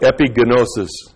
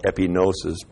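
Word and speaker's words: epignosis: Actually, it was pronounced epigenosis because they pronounced every letter. We call it epinosis, but they pronounce it epigenosis.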